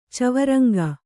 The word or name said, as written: ಚವರಂಗ